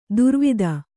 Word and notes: ದುರ್ವಾದ: kannada

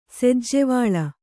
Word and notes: ಸೆಜ್ಜೆವಳ್ಳ: sejjevaḷḷa

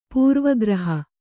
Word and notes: ಪೂರ್ವಗ್ರಹ: kannada

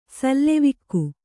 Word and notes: ಸೆಲವಿಕ್ಕು: selavikku'

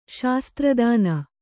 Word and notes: ಶಾಸ್ತ್ರಿತನ: śastritana; Kannada

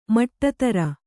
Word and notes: ಮಟ್ಟತರ: maṭṭatara

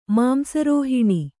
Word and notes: ಮಾಂಸರೋಹಿಣಿ: Kannada

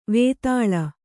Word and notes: ವೈತಾಳಿ: vaitāḷi

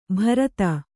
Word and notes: ಭರತ: kannada

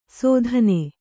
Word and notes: ಸೋಧನೆ: Kannada

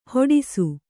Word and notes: ಹೆಡಸು: kannada

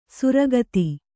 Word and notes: ಸುರಗತಿ: kannada

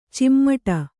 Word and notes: ಚಿಮ್ಮಟ: cimmaṭa

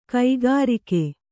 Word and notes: ಕಕ್ಕರಿಕೆ: Kannada

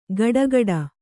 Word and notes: ಗಡಗಡ: gaḍagaḍa

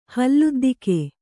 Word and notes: ಹಲ್ಲುದ್ದಿಕೆ: Kannada

